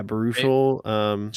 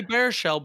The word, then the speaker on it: Baruchel: Yeah, Baruchel. – Jay Baruchel. – I used to say Bear Shell, but then someone told me I was wrong.